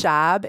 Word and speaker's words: Schaub: It's Schaub. It rhymes with Bob.